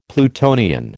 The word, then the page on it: Plutonian: American English